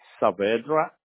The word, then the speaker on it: Saavedra: Luis, L-U-I-S, dot Saavedra. You have my last name? – Can you spell it for me? – S-A-A-V-E-D as Dr. R-A.